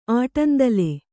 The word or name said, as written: ಆಟಂದಲೆ